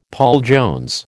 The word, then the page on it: Paul Jones: American English